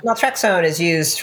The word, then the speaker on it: naltrexone: All that to say, you mentioned LDN, the low dose naltrexone. Am I saying that right? – Yeah, LDN.